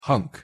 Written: hunk

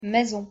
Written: maison

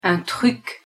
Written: un truc

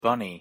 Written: bunny

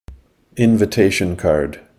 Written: invitation card

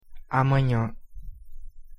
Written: amanhã

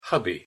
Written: hubby

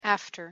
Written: after

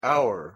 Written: our

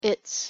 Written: its

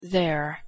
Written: there

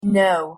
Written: no

